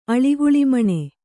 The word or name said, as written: ಅಳಿಗುಳಿಮಣೆ